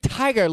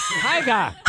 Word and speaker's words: Tyga: Tyga. – Tiger. – Tiger. – An R. – Tyga. You're with Tiger. Listen to music. – Tiger. – Tyga. Guys, I made a hard G-A. Tyga. Tyga. Tyga.